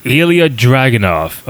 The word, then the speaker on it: Ilya Dragunov: Ilya Dragunov.